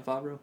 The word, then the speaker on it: Favreau: But Jon Favreau, I think that's how you say it. Jon Favreau? – Yeah.